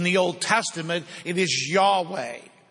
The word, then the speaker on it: Yahweh: God also said to Moses, say to the Israelites, the Lord, this Hebrew word here is the Hebrew word Yahweh, right? Or that's how we pronounce it, but it's YHWH, right? They've added the Hebrew vowels from the word Adonai, and they've come up with Yahweh.